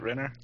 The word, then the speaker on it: Renner: Did I say that right, Renner? – Yep, Renner.